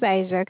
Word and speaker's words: Asia: My name is Asia.